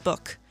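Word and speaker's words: Buch: But one of the acts I went to see was ML Bush? I think it's pronounced Book.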